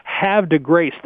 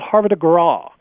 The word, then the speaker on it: Havre de Grace: The 11 horse, they've been pronouncing this as Have de Grace. There was a racetrack spelled exactly the same called Havre de Grace. And it was in Maryland.